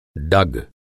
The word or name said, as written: डग